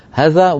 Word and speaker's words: هذا: إلى الآن نكتبها هذا، مع أننا كلنا ننطقها هذا، ومن قطأها هذا فقد أخطأ، لا يصح هذا، لابد أن يقول هذا، هؤلاء، هذين، هاتين، إلى آخره من النطق.